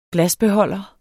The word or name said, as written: glasbeholder